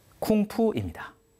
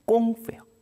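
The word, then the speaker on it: 꽁푸예요 (功夫): 이렇게 공을 세우기 위해서 애쓰는 사람 공부를 중국어로 발음하면 쿵푸입니다. 정확히는 꽁푸예요.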